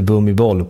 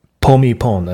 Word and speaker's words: Bhumibol: Det uttalas inte Bhumibol på thailändska. Även om jag tycker det låter kul att säga att han heter Bhumibol, kung Bhumibol. Så uttalas det snarare Pomipon eller något sånt där. B uttalas lite mer som P, och thailändare kan inte säga L i slutet av ett ord.